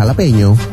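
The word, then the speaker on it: jalapeño: Iya, jalapeno. – Kok tulisannya kan jala? – Kan kalau orang Meksiko, orang Latin ngomongin gitu. J jadi H.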